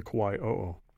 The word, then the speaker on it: Kauai o'o: Tell us about the Kauai o'o. Am I saying that right? – You are.